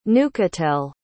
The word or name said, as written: neuchâtel